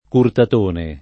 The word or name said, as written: Curtatone